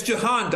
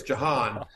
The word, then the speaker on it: Jahan: Yeah, it's Jahan Dotson, just so you know. I don't know how they pronounce it in Australia, but here in America, it's Jahan. The J is the stronger there than you pronounced it here, but what I'll just tell you is, obviously, as a Commanders fan, kid's a baller.